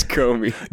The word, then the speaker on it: Comey: James Comey.